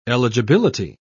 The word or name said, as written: eligibility